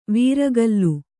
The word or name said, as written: ವಾರಿಕಲ್